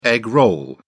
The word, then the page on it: egg roll: British English